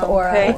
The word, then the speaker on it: Qora: No, Q-O-R-A, yeah. And probably an apostrophe after the Q. – Yeah, Ka-ora. – That makes sense. – Ka-ora.